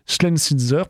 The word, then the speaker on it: Schlesinger: du nom de son fondateur Léon Schlesinger, pour la prononciation.